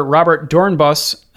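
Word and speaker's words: Robert Doornbos: Eric says, "Dear Shifters, seeing as you mentioned former Red Bull driver Robert Dornbos, you aced the pronunciation.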